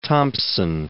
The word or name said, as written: Thomson